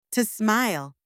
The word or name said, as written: to smile